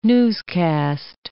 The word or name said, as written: newscast